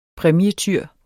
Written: præmietyr

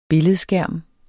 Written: billedskærm

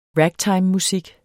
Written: ragtimemusik

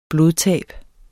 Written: blodtab

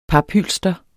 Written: paphylster

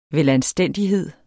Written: velanstændighed

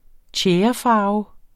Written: tjærefarve

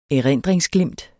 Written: erindringsglimt

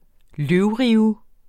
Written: løvrive

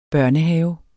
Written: børnehave

